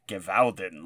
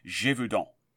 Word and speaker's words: Gévaudan: to me saying Gewalden like it was an Austrian. – Wow. Uh, whereas it should be pronounced Gévaudan.